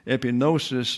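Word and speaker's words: Epinosis (ἐπίγνωσις): So here we have epi. The I there is a short I sound. That is the accented verb, the accented syllable. Epinosis. Epinosis, therefore, the G is not pronounced, by the way, here.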